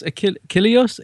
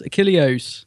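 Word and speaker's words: Achilleos: It's Chris Ilios. – Yes. – And it must be spelled correctly. No, it's Chris Achilios? Achilios.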